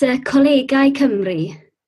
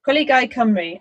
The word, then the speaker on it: Colegau Cymru: It's Colegau Cymru. – Colegau Cymru, okay.